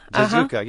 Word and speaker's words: Zazuka: And describe for us the wife in this family, and I'm hoping I'm pronouncing the last name correctly, Zazuka. Zazuka, yeah.